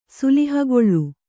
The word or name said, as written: ಸುಲಿಹಗೊಳ್ಳು